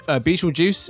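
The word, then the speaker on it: Betelgeuse: Beetlejuice is apparently how it's pronounced.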